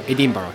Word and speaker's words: Edinburgh: Edinburgh, the Scottish.